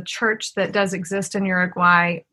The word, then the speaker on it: Uruguay: And you and your husband and family were missionaries in Uruguay.